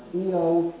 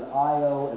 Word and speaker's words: Io: The pronunciation of Io is up to you. If you want to pronounce it Io, as I understand it, that is considered an acceptable anglicization. Io is apparently closer to what the ancient Latins would have said.